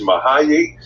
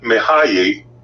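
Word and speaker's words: Mihály: And the way it is pronounced is Mahayi Csikszentmihalyi. Yeah. And I'll say that again, because it sounds like I'm tripping over my own tongue here. But Mahayi, and his last name is Csikszentmihalyi.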